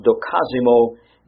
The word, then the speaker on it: dokimazo: This is a Greek word which is pronounced dokazimo, D-O-K-I-M-A-Z-O.